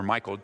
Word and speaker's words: Michal: So sad, because Saul, one of the things you see here with Merib, as well as Michal, or Michael, some people have a varying pronunciation of her name.